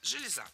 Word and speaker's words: железа: Далее разберем слово «железа», которое в начальной форме ударяется однозначно – «железа».